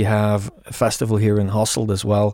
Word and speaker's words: Hasselt: So how do you say the way it looks? It looks like Hasselt, but am I... – Hasselt, yeah. Okay.